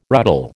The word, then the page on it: ruddle: American English